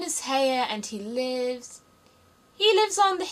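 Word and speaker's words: Haigha: His name is Heia." – He pronounced it so as to rhyme with mare.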